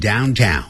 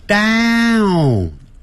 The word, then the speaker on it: downtown: 这个 downtown 呢，就是一个状语，那么就像跟 where 一样，它前面不能加 to，不能加这个介词，不能说 I'm going to downtown，要直接念 I'm going downtown，我要到市中心去。 downtown 先念大小的大，大大大大，嘴巴张得好开，downtown，不要念成 downtown，不是这样的发音呢。